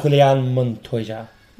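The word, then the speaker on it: Julián Montoya: Julian Montoya. – Julian Montoya. – Oh, well pronounced as well.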